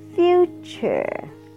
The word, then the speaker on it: future: The first one is future. Future. The first U is long.